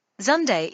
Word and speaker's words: Zunde: but please welcome Mr. Gerhard Zunde to our faculty. Crum even mispronounced his name. Zunde is 2 syllables, and Crum pronounced it as if it had 1.